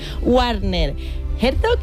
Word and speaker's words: Werner Herzog: Así que bueno, también el festival ha reconocido la trayectoria de dos grandes nombres del cine con el premio Donostia, Werner Herzog y Naomi Watts.